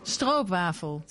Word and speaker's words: Stroopwafel: Stroopwafel. – Stroopwafel. – Oh, don't get her going. Please do not get her doing accents. Yeah. – Stroopwafel. – So the point of the Stroopwafel is that you may disagree, but when I was there, I popped it on the top of my steaming hot coffee.